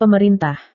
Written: pemerintah

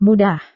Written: mudah

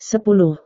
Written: sepuluh

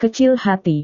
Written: kecil hati

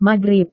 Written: magrib

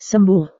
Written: sembuh

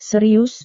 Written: serius

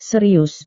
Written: serius

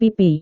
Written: pipi

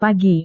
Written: pagi

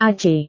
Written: ac

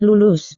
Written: lulus

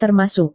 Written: termasuk

